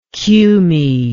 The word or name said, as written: cumaean